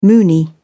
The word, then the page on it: moonie: British English